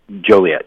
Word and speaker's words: Joliet: Did I pronounce that right? Is it Joliet? – Joliet, yeah. – Joliet, excuse me. I'm going to have to read that one as well. – It's Joliet from Joliet, Illinois.